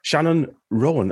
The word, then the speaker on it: Shannon Rowan: And we've got John's writing partner on this latest project, Shannon Rowan.